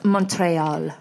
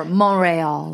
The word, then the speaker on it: Montreal: It's pronounced Montreal. – Right, because I had met him. – Or Montreal.